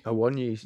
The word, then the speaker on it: Awoniyi: Awoniyi. – Awoniyi.